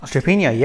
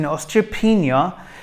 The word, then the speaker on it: osteopenia: So Dina has asked, I have osteopenia. Sorry, am I saying that right? – Osteopenia, yeah.